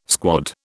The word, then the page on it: squad: British English